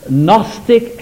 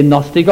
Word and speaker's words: Gnostic: Now then, there appeared in the early church in those first centuries what is known as the Gnostic heresy. Now, I never know whether to say Gnostic or Gnostic. It's spelled G-N-O-S-T-I-C.